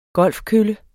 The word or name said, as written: golfkølle